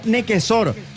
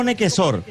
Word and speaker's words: Nequecaur: Su apellido en realidad se escribe, Fabio, Nequecaur. O sea, si lo vamos a leer literalmente, pero al ser un apellido francés, y si lo queremos pronunciar como originalmente se pronuncia en su zona de origen, es algo así como Nequesor. Entonces, para hacerlo más fácil, bueno, Nequesor. A pesar de que se escribe Nequecaur.